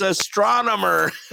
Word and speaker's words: astronomer: It was Astromener. – Astromener. – Astromener. – Scott. – How do you say that? – It's astronomer. – Say it again. Astronomer. – Yeah, astronomer? There you go.